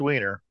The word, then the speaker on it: Weiner: Or Weiner, if you want to say Weiner. – No, it's pronounced Weiner. That's exactly right.